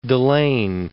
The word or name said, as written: Dalen